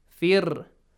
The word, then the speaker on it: Fir'aun: Fir'aun. Ra is a heavy letter. Sometimes heavy, sometimes light. Here it is sometimes light. – Yes. – Yeah? – Yes. – Sometimes light. So it is here light, lightly pronounced. Fir. Because there is kasra. – A kasra sound before.